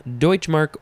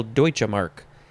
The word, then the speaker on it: Deutschmark: Deutschmark, or you can add a syllable Deutsche Mark.